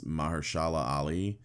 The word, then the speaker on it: Mahershala Ali: I think it's Mahershala Ali.